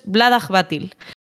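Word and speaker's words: Vlaada Chvátil: Este Claro, entonces, la forma correcta de leerlo en español, leerlo, para que te quede esa pronunciación, es como si juntas todo en una palabra y en vez de CH lo sustituyes por una J. Y si lo dices todo junto es Vladaj Batil.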